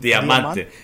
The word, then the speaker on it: Diamante: I am smoking the brand new Monte Cristo 1935 anniversary Diamante, is that how you say it? – Diamante. Yeah, diamond, but Diamante, right. Edición Diamante. Yeah.